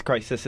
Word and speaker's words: Croesus: Crisis. Crisis is how um is the pronunciation here.